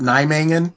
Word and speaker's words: Nijmegen: I can't pronounce this one, but like Nijmegen or whatever. Yep. Nijmegen.